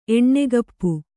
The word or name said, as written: ಎಣ್ಣೆಗಪ್ಪು